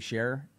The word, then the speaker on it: Scherer: Is that how you pronounce his name? Dave Scherer? – Yeah.